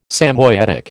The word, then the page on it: Samoyedic: American English